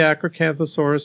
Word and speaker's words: Acrocanthosaurus: We have the Acrocanthosaurus specimen, which is a late Cretaceous dinosaur, about 112 million years. It's the best preserved of the Acrocanthosaurus specimens out there, best preserved skull of any Acrocanthosaurus.